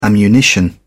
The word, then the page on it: ammunition: British English